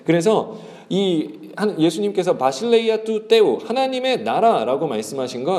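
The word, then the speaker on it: 바실레이아 (βασιλεία): 바실레이아.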